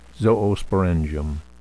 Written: zoosporangium

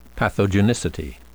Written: pathogenicity

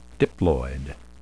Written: diploid

